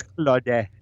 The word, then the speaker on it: Clawde: So Claude is spelled C-L-A-W-D-E. – Claude. – And it's Claude.